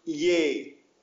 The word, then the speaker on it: यह: yah, usually pronounced ye,